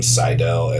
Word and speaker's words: Seidel: It's a play on words with my last name, so it's spelled Seid, like my name, Seidel, Seidel.